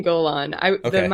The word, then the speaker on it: Golaan: I say Aspen Golaan. My last name named after the Golan Heights in Israel. So there is technically a right way to say it, but there's a range. I would say there's an acceptable range.